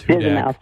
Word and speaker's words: Hudak: Yes, it is pronounced Hudak, which is great.